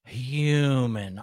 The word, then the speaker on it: human: You guys always make fun of my human. I'm sorry. It's a silent H in my world.